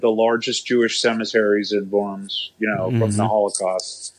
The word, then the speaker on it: Worms: It's pronounced Worms, but it's W-O-R-M-S, Worms, which is the oldest town in Germany.